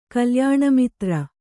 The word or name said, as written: ಕಲ್ಯಾಣಮಿತ್ರ